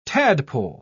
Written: tadpole

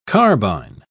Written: carbine

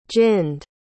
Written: ginned

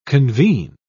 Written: convene

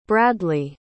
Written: bradlee

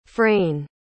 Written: fraine

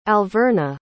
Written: alverna

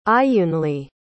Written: ayunli

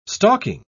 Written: stalking

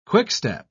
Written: quickstep

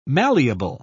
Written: malleable

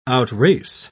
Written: outrace

